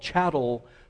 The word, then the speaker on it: chattel: First of all, there's what is called chattel slavery. Chattel rhymes with cattle, so you can think about it.